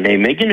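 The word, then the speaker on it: Nijmegen: Και παίζανε με την Ολλανδική, ξαναπες το όνομα γιατί εγώ δεν μπορώ να το προφέρω, γιατί δεν είναι ούτε ο Άγιαξ, ούτε η Αντιχόβεν, ούτε καν η Αλκμαρ. – Ναι, είναι η Ναϊμέγεν,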